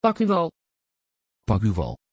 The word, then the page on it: pascual: Dutch